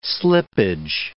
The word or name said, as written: slippage